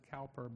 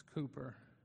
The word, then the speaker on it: Cowper: We often find ourselves like William Cooper. Some people pronounce it Cowper, but it's Cooper.